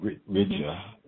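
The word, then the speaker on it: Hridaya: It's called Rigya. Mm-hmm. And Rigya is spelled Hrdiya. That's Sanskrit.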